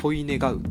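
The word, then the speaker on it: 希う: 希望の木に「う」でこれすごいぞこれ「恋願う」って読むん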